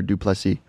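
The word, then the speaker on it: Du Plessis: Yeah, Duplessis.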